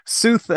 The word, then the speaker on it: Sooth: I'm your host, and today we'll be talking with Ian Baer of Sooth, or Sooth. I say that wrong every time. Oh my gosh. – Rhymes with truth. – Yep. Sooth, about the foundation of all successful marketing.